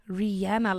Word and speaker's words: Rihanna: How do you say it? – Rihanna. But technically, it is... – I say Rihanna. – See? – Rihanna? – Rihanna. – Rihanna, like Anna. – Exactly.